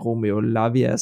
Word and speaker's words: Lavia: at jeg vist fik udtalt Romeo Lavia som Romeo Lavio eller noget. Det er jeg udmærket klar over, men smid ind med produktiv feedback i vores indbakke.